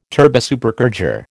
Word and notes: turbosupercharger: American English

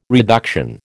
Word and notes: reduction: American English

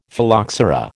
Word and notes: Phylloxera: American English

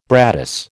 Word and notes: brattice: American English

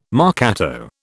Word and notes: marcato: British English

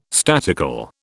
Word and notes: statical: British English

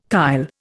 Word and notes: kyle: Indian

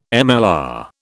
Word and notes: MLR: British English

